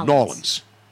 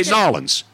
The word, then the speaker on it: New Orleans: They say New Orleans. – Yeah, that's how they say it. – It's like N-A-W-L-I-N-S. New Orleans.